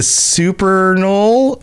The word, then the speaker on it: Supernal: After the Supernal Dreamers, that's how you pronounce that word, called him to Tamriel.